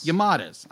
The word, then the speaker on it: Yamadas: along with My Neighbor's Yashuas. Am I saying that right? – Was what now? – My Neighbor's the Yamadas. – Yamadas. I knew I was going to say that wrong.